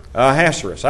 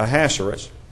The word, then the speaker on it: Ahasuerus: I can't say that word for some reason. Pronounce that for me. Ahasuerus. I got close. – Yeah. – Ahasuerus. Ahasuerus was the way that Bob was telling me to pronounce that word.